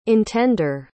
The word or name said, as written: intender